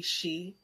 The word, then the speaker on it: shi: And she is actually spelled S-H-I. It's Japanese, but also phonetically she, as in us.